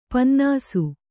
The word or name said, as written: ಪನ್ನಸು